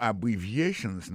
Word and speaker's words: abbreviations: Abbreviations, accent tonique la, tombe sou troisième syllabe la. Nan mot sa gen quatre syllabes. Se A, bon syllabe, bri, se deuxième syllabe la, vie, c'est troisième syllabe là, chante, c'est quatrième syllabe là.